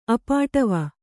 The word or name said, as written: ಅಪಾಟವ